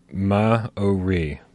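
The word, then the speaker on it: Māori: He says, he pronounced it for us, Maori, Maori. Our R's are pronounced, are trailed like in Spanish.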